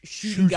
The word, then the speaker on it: Ncuti: Yeah, his name's spelled N-C-U-T-I. – Nudie. – But it's like a silent N. It's pronounced Shuti Gutwa.